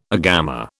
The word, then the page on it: agama: British English